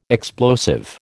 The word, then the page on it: explosive: American English